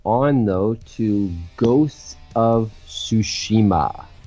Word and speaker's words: Tsushima: Well, I should know, because I took Japanese for a bit, so... Tsushima. There.